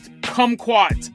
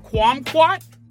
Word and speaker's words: kumquat: How do you pronounce that? Kumquat? Kwamquat? The Last Kwamquat? People like Jason, I know you have a hard time pronouncing names. This is a fruit. This is a super well-known fruit. Kwamquat, right? Kwamquat.